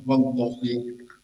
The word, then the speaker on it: wallahi: Nah, semuanya harus dibaca kasroh wallahi.